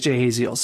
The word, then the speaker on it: Jahaziel: Now, his actual name was way more harder to pronounce than Jahaziel, but our English version of saying it is Jahaziel, so it works.